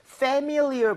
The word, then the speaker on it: familiar: 그래서 familiar 보다는 familiar. 그래서 familiar. MI 쪽에 강세를 주고 한번 발음해 볼게요.